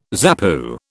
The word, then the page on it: ZAPU: British English